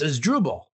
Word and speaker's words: Asdrubal: Well, he said it's Asdrubal.